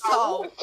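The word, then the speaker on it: Sault: It's S-A-U-L-T. – Salt? – Good. That's Salt. I think that's just Salt. – Salt? – Soup. – Salt. – Salt. – Yeah. – No, it's Salt.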